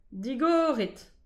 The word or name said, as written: digoret